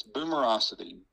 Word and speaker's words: Boomerocity: They need to remember how to spell that, like B-O-O-M-E-R O-C-I-T-Y. You can think of it as Boomer O City. That's what some people will pronounce it. But you got to have that middle zero in there, or O, not zero, but O, or you won't find it.